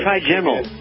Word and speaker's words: trigeminal: Is it trigonal? I can't even pronounce it. – Oh, trigeminal. Trigeminal neuralgia.